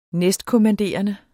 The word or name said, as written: næstkommanderende